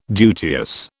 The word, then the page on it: duteous: British English